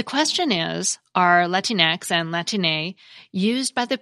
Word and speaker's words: Latine: Similar terms have popped up in English, like mix, MX, as a courtesy title, and folks, F-O-L-X, as a way to refer to commonly marginalized people. We also have Latine, but some English speakers may pronounce it Latine. Some people prefer Latine because it fits better with Spanish rules of pronunciation.